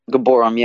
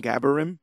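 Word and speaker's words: Gibborim: Am I saying that right? – Gaborum, yeah, that's how they pronounce it in the show. – Okay. I think when I was reading, I was saying Gaborim, but Gaborum works better.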